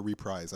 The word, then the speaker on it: reprise: Is it pronounced reprise or reprise? I've heard both. – You're saying it the English way, so yes.